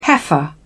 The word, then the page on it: heifer: British English